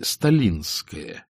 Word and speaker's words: Сталинская: Все поставки флоту корабельного леса и другие хозяйственные дела прочно держала в руках сожительница Грейга с интересной фамилией Сталинская, ударение на «и», разумеется, при помощи немалого числа допущенных к столу дельцов.